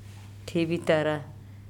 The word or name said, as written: Thebitara